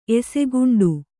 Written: ಎಸೆಗುಂಡು

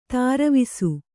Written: ತಿರುವಿಸು

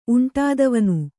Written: ಉಂಟಾದವನು

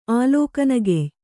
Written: ಆಲೋಕನಗೆಯ್

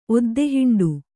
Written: ಒದ್ದೆಹಿಂಡು

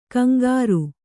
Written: ಕಂಕರಿ

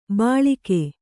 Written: ಬಳಗ

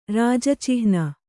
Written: ರಾಜಚಿಹ್ನ

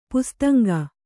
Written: ಪಿಸಿತಾಂಗ

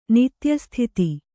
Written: ನಿತ್ಯಸ್ಥಿತಿ

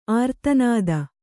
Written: ಆರ್ತನಾದ

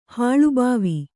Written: ಹಾಳುಭಾವಿ

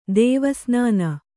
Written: ದೇವಸ್ನಾನ

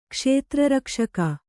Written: ಕ್ಷೇತ್ರರಕ್ಷಕ

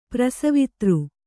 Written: ಪ್ರಸವಿತೃ

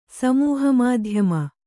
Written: ಸಮೂಹಮಾಧ್ಯಮ

